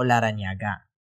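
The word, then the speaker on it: Larrañaga: The man who led the Chong sisters inside the van was identified as Francisco Paco Larangaña. Larañaga. Whoops. – Uh-oh. – I butchered that name. Let me say that again. Was identified as Francisco Paco Larañaga.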